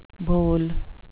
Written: boll

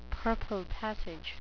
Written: purple passage

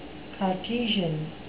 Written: cartesian

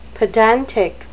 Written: pedantic

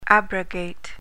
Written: abrogate

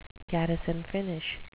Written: garrison finish

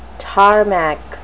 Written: tarmac

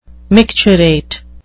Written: micturate

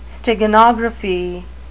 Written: steganography

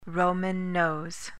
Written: roman nose

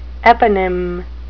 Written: eponym

